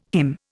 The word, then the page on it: hymn: Indian